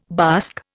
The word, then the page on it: bask: Indian